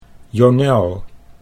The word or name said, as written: Jonel